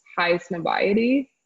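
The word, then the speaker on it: Highsnobiety: Right now, my personal favorite account to follow is High Snobiety, which hopefully we can like write a note about how that's spelled, but it's basically High Snob, and then Snobiety, like society.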